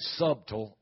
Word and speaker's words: subtle: That word is spelled S-U-B. The B is silent. It ain't subtle, it's subtle. But the Lord showed me the word subtle and the first three letters, S-U-B.